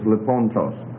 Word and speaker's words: lipontos: Here we find, for example, lipon, it's on the second syllable, lipontos. Instead of lipontos, like you would expect it, it's lipontos. See, the normal accent rules would have predicted lipontos because you can go all the way to the third syllable from the end because of the final being short. But it's not. It's lipontos.